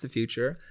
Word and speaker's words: Futur: And the future is spelled without an E at the end, so it's Futur, but it's pronounced the future.